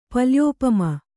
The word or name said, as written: ಪಲ್ಯೋಪಮ